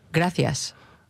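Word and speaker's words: Gracias: 正しい発音してくれる？ – 多分正しい発音言ったら誰も言えないんじゃないかな。 – ちょっと言ってみて。 – グラシアス。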